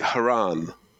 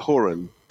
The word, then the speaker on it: Horan: because the Americans behind me were pronouncing it Haran, right, like Quran, and I was like, oh, and it's spelled Horan, and I was like, well, that's like Horan,